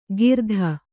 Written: ಕಿರಾತ

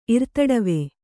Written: ಇರ್ತಡವೆ

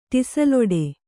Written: ಟಿಸಿಲೊಡೆ

